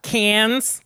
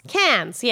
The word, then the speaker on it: Cannes: Cannes. – Cannes, yes.